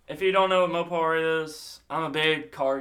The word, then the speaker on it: Mopar: Mopart. Is that how you say it? Mopart? – Mopar.